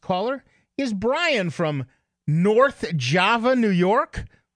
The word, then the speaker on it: Java: And it's pronounced Java. – That is correct. Well, all the locals anyways.